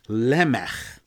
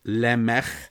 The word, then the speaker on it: Lemech: And then finally, there was somebody called Lemech. And I'm pronouncing it with the Hebrew pronunciation, with a ch at the end, like at the end of the composer Johann Sebastian Bach, Lemech, same sound.